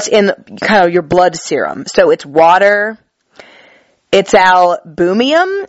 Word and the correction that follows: albumin: or albumium. Oh wait, bumin? I'm saying it wrong. Albumin. There we go. It's albumin, which is like a protein in your body.